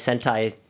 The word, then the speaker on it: Sentai: How they do in like, you know, the Japanese Sentai shows, but I can't think of what they actually yell. – See, Sentai rhymes with hentai, and that can easily be called Holtron instead of Ultron.